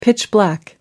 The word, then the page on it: pitch-black: British English